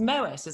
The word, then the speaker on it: Moët: So we're all saying it wrong. It's a bit like turbot, the fish. People say turbo, but it's got a T on the end. So it's Moët.